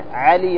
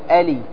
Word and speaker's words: علي: بعض أصحاب المناطق هنا في اليمن ينطقون العين همزة، يقول ا مثلا علي، يقول يا ألي ألي، هذا أصحاب تهامة يقول ألي، هذا خطأ، بل هو عالي، عاليهم عاليهم، ما تقول آليهم.